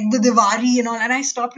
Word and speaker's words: Diwali: So actually Diwali is a good one. So yes, in English we call it Diwali, but actually we in Gujarati would say Diwali.